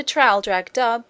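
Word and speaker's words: Traldragdubh: It contained a warrant for conducting me and my retinue to Trail-Drag-Dub, or Trill-Drog-Drib, for it is pronounced both ways as near as I can remember, by a party of 10 horse.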